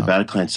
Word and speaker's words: Bataclan: Bataclan. Is that how you pronounce the venue? – Yeah, the Bataclan.